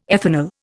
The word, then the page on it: ethanol: Indian